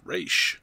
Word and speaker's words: Ra's: Yes, pronounced Ra'sh.